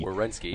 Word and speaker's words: Werenski: I can't even say his name. Werenski. – Werenski, yes.